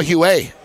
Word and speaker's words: Huet: And after he got mad one day, he goes, you're all saying my name wrong. – My name? – Remember, Cristobal Huet. Huet. – Yeah. Huet. Huet. He's French, though. – It's Huet.